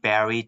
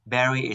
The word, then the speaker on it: Barrie: Or you can type my name, Barrie Tan. Barrie is spelled B-A-R-R-I-E.